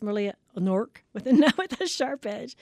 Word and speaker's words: Knork: It's a Nork. – Nork. – How do you spell that? – K-N-O-R-K.